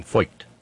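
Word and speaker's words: Feucht: Yeah, Kid Mark in the chat room reminded me of the proper pronunciation, Foyt.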